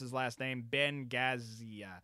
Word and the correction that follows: Gazzara: I think it's Gazzara.